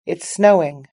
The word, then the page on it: it's snowing: British English